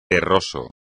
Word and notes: terroso: espagnol

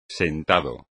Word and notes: sentado: espagnol

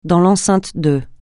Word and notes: dans l'enceinte de: francais

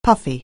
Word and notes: puffy: anglais

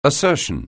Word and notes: assertion: anglais

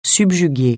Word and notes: subjuguer: francais

